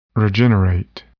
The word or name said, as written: regenerate